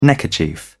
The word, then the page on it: neckerchief: British English